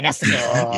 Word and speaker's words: Nesticle: So when I was in college, this thing called Nesticle came out. – Yeah, Nesticle. – Yeah. – Rhymes with testicle.